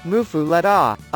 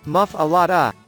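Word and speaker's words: muffuletta: and is pronounced mufu lada. Others in the Big Easy call it muff a lada.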